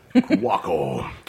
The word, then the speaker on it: Cuoco: Cuoco.